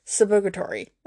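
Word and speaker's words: Suburgatory: Anyways, so I know a couple weeks ago I talked about Subictoria. Subrogatory. That's how you say it.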